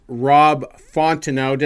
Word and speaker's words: Fontenot: If you're going to listen to an Astros podcast, that is the one to listen to, with their host, Rob Fontenot. Did I say that right? – Said it perfectly.